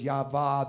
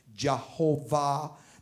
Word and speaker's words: Yahweh: Well, for many, many years, you know, they've only been saying that it was pronounced Yahweh for about 25 years. Before that, they weren't sure. They thought it was Yahweh. Then they thought it was Jehovah.